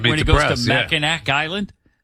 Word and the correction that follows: Mackinac: Oh, no, Mackinac Island. – Mackinac Island. – First of all, yeah. – It's spelled, why do they spell it Mackinac when it's Mackinac?